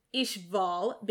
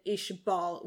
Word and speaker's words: Ishval: It's written with an wa, which is very specifically used in katakana to serve as a V, which is why we will say Ishval, because it is more in line with the katakana, rather than Ishbal, which is in line with the technical pronunciation. – Mm-hmm. – So we believe that basically Arakawa intended for it to be Ishval instead of Ishbal, because she wrote it Ishval.